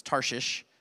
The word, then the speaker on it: Tarshish: It sounds like you have a list, but it's Tarshish. It's actually how you say it.